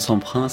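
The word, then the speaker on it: Semprun: Nous allons prononcer à la française le nom de Semprin, c'est plus sûr.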